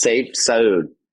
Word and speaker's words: Sayif Saud: How do you say your name correctly? Ah. I said it's Sayif Saud.